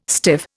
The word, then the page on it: stiff: Indian